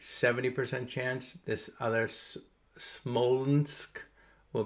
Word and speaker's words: Smolensk: I can't even pronounce it. Smolensk. Smolensk. Sounds like smokes, but not quite.